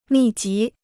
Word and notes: 密集: mì jí

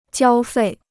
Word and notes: 交费: jiāo fèi

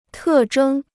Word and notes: 特征: tè zhēng

